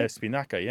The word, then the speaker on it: espinaca: Espinaca, right? – Espinaca, yeah. Spanish keeps it.